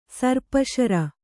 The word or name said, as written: ಸರ್ಪಶರ